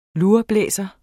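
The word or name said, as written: LURBLÆSERE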